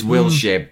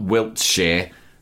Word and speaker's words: Wilshere: It's Wilshere, not Wiltshire.